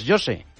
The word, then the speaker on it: José: Pero José, en portugués, José.